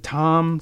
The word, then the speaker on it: Thom: Thom Stanton, Thom with a H. – Thom. – Yeah, Thom. Welcome to the show. – I bet the H is silent.